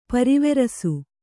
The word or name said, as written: ಪರಿವರಿಸು